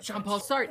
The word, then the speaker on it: Jean-Paul Sartre: I'm not a Jean-Paul Sartre.